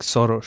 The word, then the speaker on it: Soros: The first thing you need to know about George Soros is you're saying it wrong. You say like Soros.